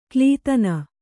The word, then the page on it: ಕ್ಲೀತನ: Kannada